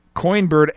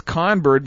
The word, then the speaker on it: Coinbird: Yes, Beef, I pronounced Coinbird as Conbird because it was spelled that way.